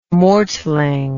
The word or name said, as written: mortling